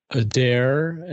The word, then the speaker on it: Adair: So I'm going to go with the 4 horse, Adair. – Adair, and that is how you pronounce it. I will say that, having watched Trevor Denman pronounce it that way.